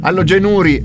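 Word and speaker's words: alogenuri: Ma non era allogenuri. – Allogenuri, è vero, hai ragione, l'accento è sulla U.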